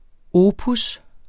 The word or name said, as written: opus